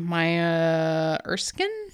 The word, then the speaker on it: Maya Erskine: It's going to have Donald Glover and Maya Erskine.